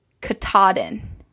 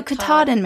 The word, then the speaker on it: Katahdin: So I had said that the Appalachian Trail runs from Springer Mountain, Georgia to Mount Katahdin, Maine, and I pronounced the name wrong in Maine. My mom was like, probably no one noticed or knows how you actually say it, but I'm going to tell you because she's from New Hampshire. It's Katahdin.